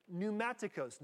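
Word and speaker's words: pneumatikos: It's another word, pneumaticos. Now, I don't know where Rory is, but I'm sure he's going to say I'm mispronouncing both these words. Am I, Rory? Pretty good? Oh, pretty good.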